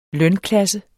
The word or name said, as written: lønklasse